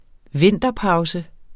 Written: vinterpause

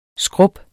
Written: skrub

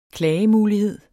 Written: klagemulighed

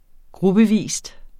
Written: gruppevis

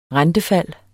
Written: rentefald

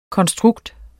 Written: konstrukt

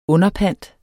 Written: underpant

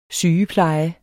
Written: sygepleje

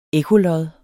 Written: ekkolod

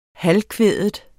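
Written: halvkvædet

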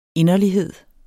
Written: inderlighed

